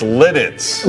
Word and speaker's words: Lititz: Oh, it's Lititz.